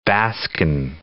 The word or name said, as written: Baskin